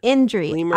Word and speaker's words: Indri: Indri. I-N-D-R-I.